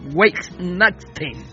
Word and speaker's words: Weihnachten: pero se pronuncia Weihnachten, que significa noche de bendición.